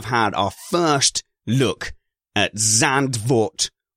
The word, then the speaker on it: Zandvoort: The one I always go with is Zandvoort, because that's how it's spelt, and it's the one I hear the most.